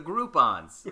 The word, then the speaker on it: Groupon: Ah, yes, for the Groupons. Yeah. Rhymes with coupons.